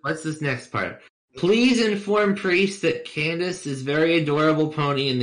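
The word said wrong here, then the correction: Cadance: It's Cadence, not Candace.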